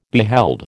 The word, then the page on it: beheld: American English